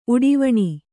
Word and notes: ಉಡಿವಣಿ: Kannada

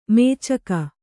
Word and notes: ಮೇಚಕ: mēcaka

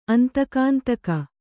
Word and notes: ಅಂತಕಾಂತಕ: Kannada

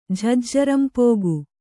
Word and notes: ಝಜ್ಜರಂಪೋಗು: jhajjaram pōgu

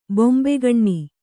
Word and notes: ಬೊಂಬೆಗಣ್ಣಿ: bombegaṇṇi

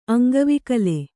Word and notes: ಅಂಗವಿಕಲೆ: Kannada

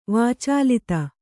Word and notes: ವಾಚಾಲತೆ: vācālate

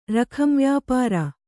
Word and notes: ರಖಂವ್ಯಾಪಾರ: kannada

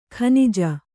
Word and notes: ಕೊಞ್ಞೆ: Kannada

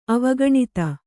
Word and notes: ಅವಗಣಿತ: Kannada

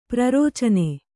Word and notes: ಪ್ರರೋಚನೆ: prarōcane

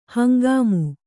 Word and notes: ಹಂಗಾಮು: hangāmu

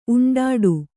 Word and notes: ಉಂಡಾಡಿ: Kannada

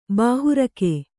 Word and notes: ಬಾಹುರಕ್ಕೆ: bāhu rakke

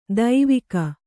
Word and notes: ದೈವಿಕ: daivika

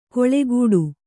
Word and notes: ಕೊಳೆಗೂಡು: Kannada

